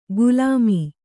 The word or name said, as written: ಗುಲಾಮೀ